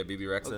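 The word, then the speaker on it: Bebe Rexha: How do you say that? BB Rexha? – Yeah, BB Rexha.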